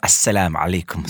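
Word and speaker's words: assalamu alaikum: Eller 'assalam alaikum', som ja, vi sier. På, du kan si det! Selvfølgelig. Det er riktig, det er riktig uttale.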